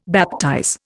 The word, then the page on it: baptize: Indian